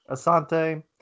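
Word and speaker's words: Assante: How do you say his last name? – Armand Asante.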